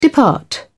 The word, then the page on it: depart: British English